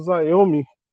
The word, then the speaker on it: Xiaomi: A China tem mandado fábrica para lá, por exemplo, o Xiaomi, o Xiaomi, o Xiaomi, pronúncia mais ou menos assim em chinês, o celular, o tem deles fabricado na China, mas tem muito mais fabricado na Índia.